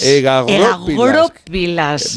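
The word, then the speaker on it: egagrópilas: Egagoropilas. Vamos a ponerlo... – El acento en la O.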